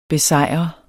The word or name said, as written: besejrer